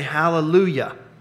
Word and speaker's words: hallelujah: If God is blessing the ministry of the word, then we say hallelujah with an emphasis on the Yah, which means God, right, in Hebrew.